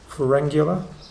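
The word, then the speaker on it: Pharyngula: Ferengula, am I pronouncing that wrong? – No, I think that's right.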